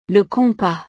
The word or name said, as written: le compas